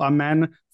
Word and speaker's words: Amen: Apparently we're enunciating the second half of the second syllable. Amen.